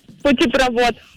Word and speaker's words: путепровод: Это сложное слово. Образованное от двух других. А в таких, как известно, ударение всегда падает на второй корень. А он тут вот. Следовательно, правильно говорить путепровод.